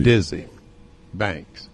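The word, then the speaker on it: Desi Banks: They can go see Dizzy Banks, or Diaz Banks, however his name is pronounced.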